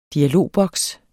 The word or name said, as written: dialogboks